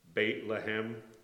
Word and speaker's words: Beit Lehem: Ironically, that's pronounced Beit Lehem, which means the house of bread.